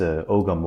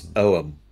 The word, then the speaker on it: Ogham: It's Ogham. It's not Ogham. It's Ogham. Or probably.